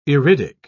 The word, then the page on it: iridic: British English